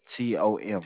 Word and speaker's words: Tom: And your first name is Tom, T O M.